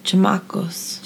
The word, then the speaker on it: chamacos: How would you say this, babe? Chamacos? Chamacamos? – Chamacos. – Chamacos.